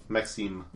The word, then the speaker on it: Maxim: I'm Maxime. – Maxime? – Maxime. – Maxime. – How do you spell that? – M-A-X-I-M. – Maxime. – Or Maxim, if you'd like.